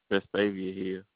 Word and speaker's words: Vestavia Hills: Vestavia Hills, Alabama. – Vestavia Hills, yeah.